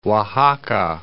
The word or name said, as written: Oaxaca